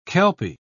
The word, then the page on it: kelpy: British English